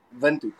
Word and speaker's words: Windhoek: Windhoek. – Windhoek. We are way off. – We have to write that down. – Wind, like wind, you spell it the same way. W-I-N-D, Windhoek, like corner.